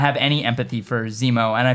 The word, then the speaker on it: Zemo: If not for Zemo, because I think that's what left a bad taste in my mouth. I'm like, this character is, He rhymes with Nemo. – Yeah. Finding Zemo.